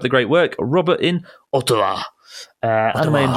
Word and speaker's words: Ottawa: Ottawa, who says, why am I pronouncing it like a Japanese way? Ottawa. Not Ottawa.